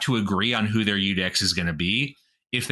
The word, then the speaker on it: iudex: In a civil case, the praetor appoints what's called a iudex, spelled like judex, but it's Latin, so it's iudex, which is where the word judge comes from.